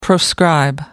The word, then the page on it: proscribe: American English